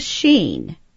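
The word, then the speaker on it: Shin: Now, I must tell you, could the Sheen, which is God's initial, that's how you pronounce it, it stands for Shaddai, and it actually looks like a W if you were looking at it.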